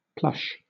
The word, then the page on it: plush: British English